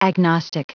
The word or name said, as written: agnostic